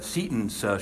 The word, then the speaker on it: Seton: He was a bishop from France, and he kept mispronouncing her name, Satan, and so somebody finally went up and whispered in his ear, it's Seton, ah, Seton, Seton.